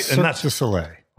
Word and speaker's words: Cirque du Soleil: I say Cirque du Soleil, right? – Cirque du Soleil.